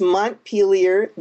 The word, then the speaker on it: Montpelier: For one reason, most people don't know how to pronounce it. Let me just set the record straight and say it's Montpelier, the capital of Vermont, and that is one of the three cities where this book takes place.